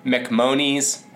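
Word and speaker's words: MacMonnies: At the top, sculptor Frederick Mahomes, well, a little mispronunciation there, McMonies,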